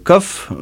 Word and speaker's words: Kob: L'album s'appelle le KOF, mais ça s'écrit K-O-B, mais ça se dit KOF, voilà.